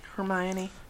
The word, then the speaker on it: Hermione: How do you say her name? – Hermione.